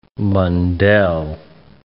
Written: Mundell

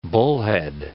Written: bullhead

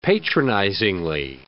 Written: patronizingly

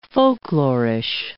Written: folklorish

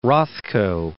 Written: Rothko

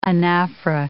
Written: anaphora

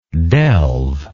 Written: delver

